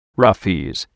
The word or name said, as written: Roughies